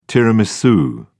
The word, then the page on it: tiramisu: British English